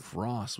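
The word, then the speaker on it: Rohss: R-O-H-S-S. H is silent.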